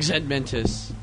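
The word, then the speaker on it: Zed Mentis: Yeah, well, I go by my gamer tag, Zedmentus. – How do you spell that? – Z-E-D space M-E-N-T-I-S.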